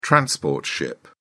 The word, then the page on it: transport ship: British English